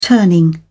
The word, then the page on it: turning: British English